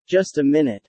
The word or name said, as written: Just a minute